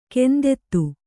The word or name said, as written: ಗಂಧಿತ